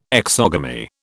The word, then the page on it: exogamy: British English